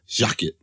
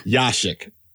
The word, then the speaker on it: Jaschek: Jaschek. Shai Jaschek. – It's like actually a Y, not a J. Like it's, you know, it's spelled with a J, but it's a Y sound. Y-A-S. Jaschek.